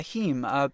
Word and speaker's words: heme: Yeah, heme.